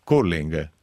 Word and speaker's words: curling: Curling, com'è che si pronuncia? – Curling.